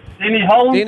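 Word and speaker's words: Hulme: Rhymes with Penny. Malm. – Penny Malm. Five. Four. Three. – Denny Holm.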